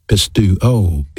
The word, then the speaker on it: pisteuo: You know, faith is a noun, and in the Greek language it's pronounced pistis, P-I-S-T-I-S. It has a verb counterpart, and that's the word believe, pistuo, P-I-S-T-E-U-O.